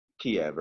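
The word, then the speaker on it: Kyiv: And that is Kiev, or Kiev, if you prefer the Ukrainian pronunciation. I should say Kiev to respect the Ukrainians.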